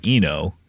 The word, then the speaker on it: Eno: What we know is, and you know, we know rhymes with Eno. Brian Eno.